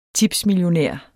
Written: tipsmillionær

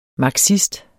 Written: marxist

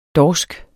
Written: dorsk

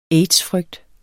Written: aids-frygt

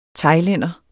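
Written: THAILÆNDER

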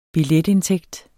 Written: billetindtægt

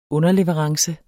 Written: underleverance

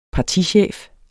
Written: partichef

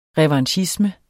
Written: revanchisme

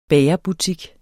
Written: bagerbutik

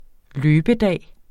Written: løbedag

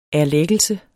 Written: erlæggelse

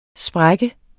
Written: sprukken

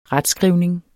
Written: retskrivning

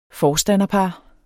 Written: forstanderpar

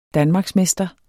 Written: danmarksmester

